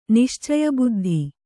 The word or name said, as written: ನಿಶ್ಚಯಬುದ್ಧಿ